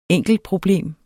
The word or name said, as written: enkeltproblem